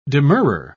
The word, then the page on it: demurrer: British English